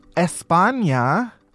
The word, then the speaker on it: España: First, a man was arrested in España. That's how the locals say it.